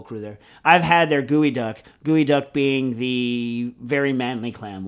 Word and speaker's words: geoduck: Yes, spell geoduck, pronounced gooey duck.